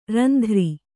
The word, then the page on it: ರುಂದ್ರ: rundra